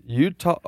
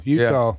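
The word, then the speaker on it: Eutaw: It is like hee-haw, Utah. – Yeah, I would spell it like Utah, the state. – Yeah, well, I think it's pronounced that way. Utah. – It is. – U-T-A-W.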